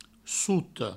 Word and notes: sota: souto

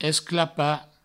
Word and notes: esclapar: esclapa; provençal

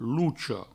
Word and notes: locha: provençal; loucho

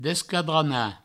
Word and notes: desquadranar: Provençal; descadrana

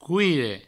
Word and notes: coire: couire

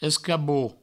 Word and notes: escabot: escabouot; provençal

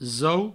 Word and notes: zo: zou

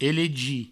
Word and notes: elegir: elegi; Provençal

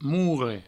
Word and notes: morre: mourre

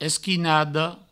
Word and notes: esquinada: provençal; esquinado

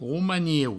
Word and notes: romaniu: roumanieu; Provençal

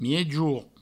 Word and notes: miejorn: miejour

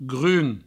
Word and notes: grum: Provençal

